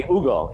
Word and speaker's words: Hugo: It should be Hugo. It should be Hugo. – I thought so too. – It's age is silent in Spanish.